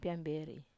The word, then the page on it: piamber(i): wamesa